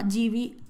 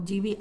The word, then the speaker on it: Gibi: Pero bueno, si les llama la atención, eh, mi youtuber americana favorita se llama GB ASMR o GB ASMR, se escribe G-I-B-I ella es de las americanas, mi favorita, tiene 2 millones de seguidores o así, es bastante famosa.